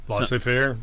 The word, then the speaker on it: laissez-faire: What's that expression pronounced? Laissez-faire? – Laissez-faire? – Something like that.